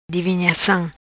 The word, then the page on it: Divinhacam: Patua